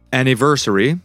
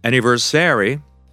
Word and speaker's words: anniversary: The correct pronunciation is anniversary. So the stress falls on the third syllable. Anniversary. 1, 2, 3, 4, 5. It's a long word. 5 syllables. The third one is stressed.